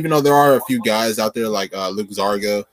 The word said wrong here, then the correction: Luka Garza: who's been playing like exceptional basketball, and he's probably gonna continue to tear the Luka Garza, my bad. I said his name wrong.